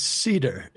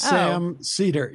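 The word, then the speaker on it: Seder: How do you say it? – It is, it's Cedar. It's Sam Cedar, yeah.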